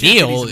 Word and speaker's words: Neil: Niall? – I think called his... – No, it's Neil. His last name is Blomkamp.